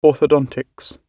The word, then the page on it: orthodontics: British English